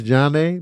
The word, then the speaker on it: Zhané: Oh, Zhané? Oh man, don't get me started on that album. That's one of my favorite albums. Pronounced Jah-Nay, man, fire.